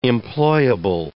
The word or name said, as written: employable